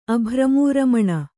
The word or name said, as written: ಅಭ್ರಮೂರಮಣ